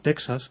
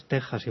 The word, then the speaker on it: Texas: Eso pasó con México, pasó con Texas, que mucha gente tiende a pronunciar como Texas. – Sí, es verdad, Texas. – A la americana, y es Texas, igual que se dice México, y con algunas ciudades más de México.